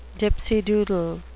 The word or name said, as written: dipsy doodle